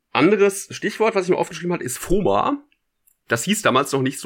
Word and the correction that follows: FOMO: FOMO, Entschuldigung, nicht FOMA. Out schreibt man ja, ja mit aber O macht und nicht mit A. FOMO ist völlig, ja.